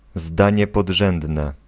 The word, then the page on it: zdanie podrzędne: /ˈzdaɲɛ pɔdˈʐɛ̃dnɛ/